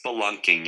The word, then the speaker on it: spelunking: Spurlunk. What's the Spelunk? – Spelunk? Is that really how you say it? It's not spur, it's spelunking? – Yeah. It's spelunking, yeah.